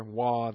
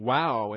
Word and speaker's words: Vav: And we move on this morning to Vav. Sometimes pronounced with a more of a W sound than a V sound, so it's more wah than vav. Or it's even wow in some pronunciations I've heard.